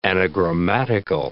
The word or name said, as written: anagrammatical